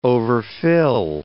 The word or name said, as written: overfill